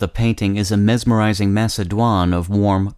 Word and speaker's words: macédoine: The word of the day for November 24th is Macédoine, spelled M-A-C-E-D-O-I-N-E. The first E in Macédoine is spelled with an acute accent rising to the right.